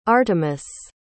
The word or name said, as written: artemis